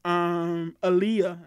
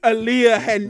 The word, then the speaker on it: Aaliyah: No one would have looked at her if Aaliyah, I got her name right this time, right? – Yeah. – Yes. – Aaliyah hadn't died.